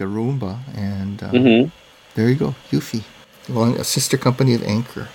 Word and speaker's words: Eufy: And and it is pronounced Eufy, like you said.